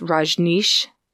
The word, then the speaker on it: Rajneesh: The Church of the Lamb of God, the Rajneesh, how do you pronounce it? It's Rajneesh, the Rajneesh group.